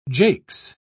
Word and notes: jakes: British English

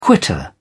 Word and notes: quitter: British English

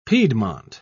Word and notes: piedmont: British English

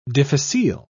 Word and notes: difficile: British English

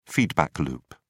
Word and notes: feedback loop: British English

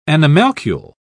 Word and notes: animalcule: British English

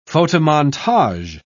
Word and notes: photomontage: British English

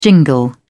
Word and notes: jingle: British English

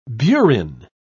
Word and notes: burin: British English

